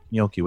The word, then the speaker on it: gnocchi: Is that how you say it? Is that right? – Gnocchi. – Gnocchi with more of a M sound, mm-hmm. right? – No, it's perfect.